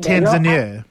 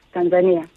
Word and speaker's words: Tanzania: You pronounce the word Tanzania. It's actually neither. Kiswahili is a very straightforward language. The way you write it, it's the way you pronounce it.